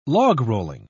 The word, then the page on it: logrolling: British English